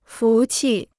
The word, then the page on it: 福气: fú qi